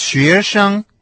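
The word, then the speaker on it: шуэшэн (学生): Слово «студент» по-китайски будет «шуэшэн». Первый компонент со вторым тоном «шуэ», а второй тона не имеет, то есть произносится с легким тоном.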